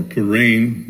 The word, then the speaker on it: Purim: If I had planned better, I would have preached this sermon 2 weeks ago at the Jewish festival of Purim, or Purim, if you prefer the Hebrew pronunciation, which is about the book of, which is instituted at the end of the book of Esther.